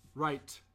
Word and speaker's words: right: Right. You always have to pronounce the T at the end.